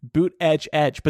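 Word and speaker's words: Buttigieg: He's saying we need to pronounce it Boot Edge Edge, but that does not roll off the tongue.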